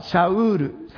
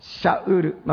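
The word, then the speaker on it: シ ャ ウール (שאול): サウルのヘブル語表記、ヘブル語の発音はシャウール、先週も学びましたけれども、シャウール、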